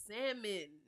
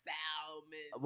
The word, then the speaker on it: salmon: See, I always they like a salmon oh, with with with a salmon. It's no L. The L is silent. It's salmon, not salmon.